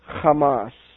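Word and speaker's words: Hamas (חמס): The word violence is the Strong's number 2555, and it is pronounced in Hebrew, Hamas.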